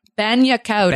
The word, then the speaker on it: bagna cauda: Did I pronounce bagna cauda correctly? – Take the G out. Bagna cauda.